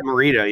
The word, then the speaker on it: Morita: And they're like, "Yeah, that's Pat Morita, or however you pronounce it. Pat Morita, yeah.